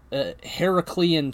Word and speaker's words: Heraclean: Heraclean task, and yes, I said that correctly. His name is Heracles, not Hercules.